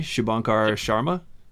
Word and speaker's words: Shubhankar Sharma: This one you're going to pronounce for me. Shubhankar Sharma? – Yep, you nailed that.